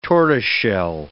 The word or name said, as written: tortoiseshell